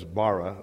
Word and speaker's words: bara: One of them is bara. It means B-A-R-A, which means to create.